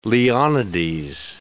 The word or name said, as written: Leonides